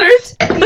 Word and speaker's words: Schweinfurt: In 1814, a company in Schweinfurt. – Wow. – Germany. – I cannot. – Schweinfurt, yes.